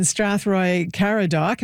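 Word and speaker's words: Strathroy-Caradoc: John is in Strathroy Caradoc.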